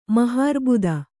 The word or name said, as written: ಮಹಾರ್ಬುದ